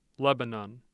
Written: Lebanon

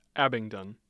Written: Abingdon

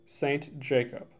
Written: St. Jacob

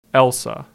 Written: Elsah

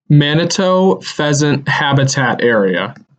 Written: Manito Pheasant Habitat Area

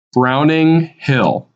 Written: Browning Hill

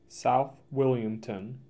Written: South Wilmington

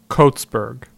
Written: Coatsburg

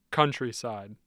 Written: Countryside

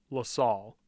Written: LaSalle

